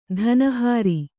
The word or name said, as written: ಧನಹಾರಿ